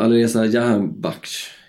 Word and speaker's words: Alireza Jahanbakhsh: Jag minns ju den här eh, eh, Alireza Jahanbakhsh heter han ju.